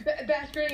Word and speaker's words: Beskar: Is it Vascar? – Hmm. – What, how's it pronounced, the metal? – Beskar steel, I think. – Beskar, yeah, okay. I didn't know if it was Beskar.